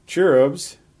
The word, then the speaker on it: cherub: But these cherubs, or cherubs, as they would be pronounced in Hebrew, cherub, are a fantastic creature, and these four apparently are particularly privileged by surrounding God's throne.